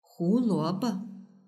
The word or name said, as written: huluobo